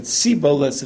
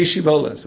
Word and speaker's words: Shibboleth: So they made them say Shiboles in order to be able to, they said Siboles if they couldn't say it, right? They couldn't say it properly. So to know who's on your team, you say Shiboles.